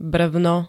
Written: brvno

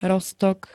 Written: roztok

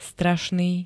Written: strašný

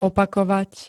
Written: opakovať

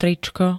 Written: tričko

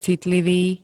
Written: citlivý

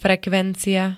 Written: frekvencia

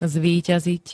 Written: zvíťaziť

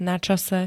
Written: načase

